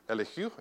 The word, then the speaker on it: Elihu: We say Elihu, but it's out pronounced more Elihu. Elihu is the more correct pronunciation.